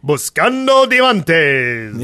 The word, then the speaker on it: Buscando Diamantes: Yes, and evidently Estragali says you pronounced the title perfectly. – I did? – Yep. – Yay. – Buscando Diamantes.